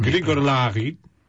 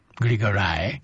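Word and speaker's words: Griogair Labhruidh: więc ja może przeliteruję. To był pan, który nazywa się Gryogair Labhruidh. Czyta się to imię i nazwisko. – Jak z Władcy Pierścienia, tak, tak brzmi. – Grigor Lahi.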